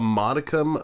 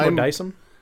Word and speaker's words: modicum: The other one, if there's a modicum of understanding of game mechanics, because it's not pronounced Modaisum.